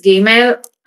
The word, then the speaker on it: Gimel: This is Gimel. So the Gimel is the third letter of the Hebrew alphabet. The pronunciation is similar to the letter G, as in gap in English.